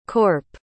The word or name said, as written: corp